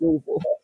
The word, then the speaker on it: Louisville: If you're from Louisville, you got to say it like that. Louisville.